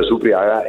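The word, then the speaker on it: Supriaga (Супряга): Io non ho Supriaga. Okay. Si scrive Supriaga, però si pronuncia Supriaga,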